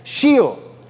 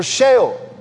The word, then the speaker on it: Sheol: The depths of the earth, the psalmist in other places refers to this as Sheol or Sheol, depending on your preferred pronunciation.